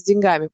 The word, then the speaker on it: деньгами: А также и с деньгами. Поэтому куда ударение вообще ставится, я не знаю. – Деньги, денег. Деньгами. – Но, деньгами.